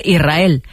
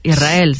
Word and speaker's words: Israel: Y la S con la R es difícil de pronunciar. La gente dice Israel, sin verdad que suene la S. Israel, sí, es difícil de pronunciar.